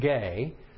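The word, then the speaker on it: gay (γῆ): It's pronounced gay. It's a long E, G-E.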